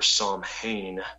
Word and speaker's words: Samhain: So that's sort of where Psalm Hain, and that's spelled, if you want to look it up, S-A-M-H-A-I-N.